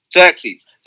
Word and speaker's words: Xerxes: Xerxes.